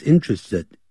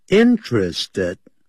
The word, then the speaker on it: interested: 但是保罗却没有兴趣 is interested in 好这个固定的搭配啊 is interested in 对什么有兴趣 interested 不要念成 interested 这个是念太复杂太难 interested interested 那个的把它停顿 interested 要这样念